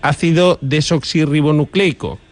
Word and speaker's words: ácido desoxirribonucleico: esto del ácido desoxirribonucleico.